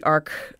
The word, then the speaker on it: Arch: That's called the ARC 1.2. It's spelled like arch, but it's pronounced like arc.